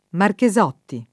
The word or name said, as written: Marchesotti